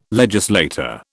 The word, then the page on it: legislator: British English